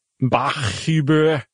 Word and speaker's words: Bachhuber: Bachhuber. – B-A-C-H, which is fine. That's clearly Bach. And H-U-B-R. You just don't often see two H's side by side. – No. – So anyway, Kevin Bachhuber.